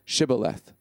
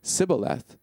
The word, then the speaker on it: Shibboleth: If he said, "No," then they said to him, "Say, Shibboleth." If he said, "Sibboleth," and could not pronounce the word correctly, they grabbed him and executed him right there at the fords of the Jordan.